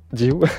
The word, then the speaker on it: Diou: Voici Diou. On prononce comme ça, je crois. Hein, Diou, exactement.